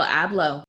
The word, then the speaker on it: Abloh: Yeah, Virgil Abloh.